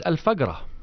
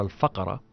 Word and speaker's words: Al-Faqra: It's Al-Fagra, you know, the local pronunciation in Saudi Arabic. It's Al-Fagra or Al-Faqra.